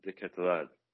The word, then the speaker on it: De Ketelaere: Yeah, I think, from what I heard, it's pronounced Charles de Ketelaere.